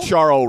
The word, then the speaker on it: Charles: You don't need a Charo ranking. – Or what about Leclerc? – What about Formula One? Ferrari team? – Charl? C-H-A-R-L? – It ends with an S, but it's pronounced Charo.